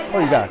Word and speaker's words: Khuygaa: My name is Koiga. – How do you spell that? – K-H-U-Y-G-A-A.